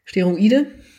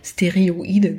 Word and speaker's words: Steroide: Weil hatte ich ja schon mal erzählt, das ist diejenige, ähm, die ja eine, äh, des Dopings überführt wurde, Steroide. Mhm ich weiß immer nicht, ob ich es richtig aussprechen will. Ich wurde schon mal heftig ausgelacht, weil ich glaube, ich Stereoide gesagt habe.